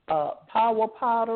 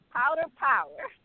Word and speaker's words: Powder Power: Is that power powder or how do you say it? Power powder? – Yes, ma'am. Right, it's a tongue twister, beloved. Yes, powder power.